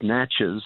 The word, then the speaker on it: Natchez: We were looking at it, it looks like it's said, pronounced Natchez, as in Natchez, Mississippi, where this mound site was, but they call themselves the Na'Chi.